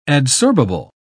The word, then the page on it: adsorbable: British English